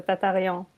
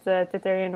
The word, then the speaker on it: Tatarian: I pronounce it the French way, so Tatarian, but Tatarian works as well, so.